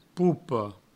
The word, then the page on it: popa: poupo; provençal